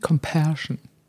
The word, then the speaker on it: compassion: Compassion. Habe ich das richtig ausgesprochen? – Yes.